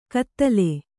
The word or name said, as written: ಕದಲೆ